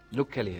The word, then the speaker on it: Luke Kelly: Y acá en la lista tengo a Luke Kelly, ¿se dice así? – Luke Kelly, exactamente.